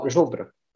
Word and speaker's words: Żubr: Oh, Żubr.